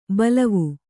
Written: ಬಲವು

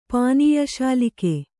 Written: ಪಾನೀಯಶಾಲಿಕೆ

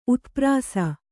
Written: ಉತ್ಪ್ರಾಸ

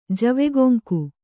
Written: ಜವೆಗೊಂಕು

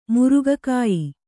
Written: ಮುರುಗಕಾಯಿ

